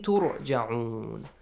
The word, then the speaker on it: تُرْجَعُونَ: So, إِلَيْهِ تُرْجَعُونَ So, the letter Ra has to be pronounced heavily since it has a Dhamma sound before. Yes?